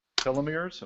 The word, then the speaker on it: telomeres: telomeres.